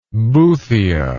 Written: boothia

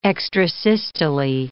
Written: extrasystole

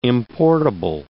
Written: importable

